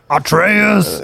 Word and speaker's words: Atreus: At, äh, at, äh, ja, Atreus wird bei uns ausgesprochen. Äh, Atreus. Atreus. Ja, Atreus. Okay, gut.